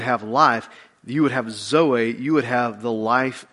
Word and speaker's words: Zoe: It's called Zoe. It's Z-O-E, Zoe.